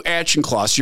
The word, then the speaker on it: Auchincloss: And at the top of this page, you sent this email to Hugh Auchincloss. Yeah, correct. Is that how you say his name? Yeah, that's correct. You sent this email to Hugh Auchincloss, your principal deputy.